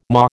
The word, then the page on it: maw: American English